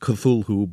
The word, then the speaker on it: Cthulhu: C-T-H-U-L-H-U. It looks as if it should be pronounced Cthulhu, but he maintained in a letter written much later that it's really two syllables, and the first one is sort of coughed or barked out.